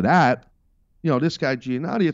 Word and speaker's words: Giannotti: Giannotti? How do you say his name? – Greg Giannotti.